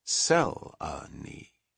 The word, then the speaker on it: Selenie: I am Selenie, all three syllables." – "Sel-a-nee," she said, coming down heavily on the first syllable, "to those who use my first name at all."